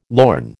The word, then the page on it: lorn: American English